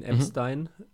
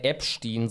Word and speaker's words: Epstein: Epstein. Deutsche Aussprache ist vielleicht ein bisschen besser. Aber ich sage immer gerne Epstein, so wie Epstein. Dieser Fisch aus American Dad.